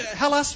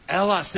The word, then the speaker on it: Hellas: Is it Hellas? Fix Hellas? – Hellas. – Elas. The H is silent.